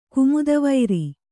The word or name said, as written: ಗಾಮದೆವರು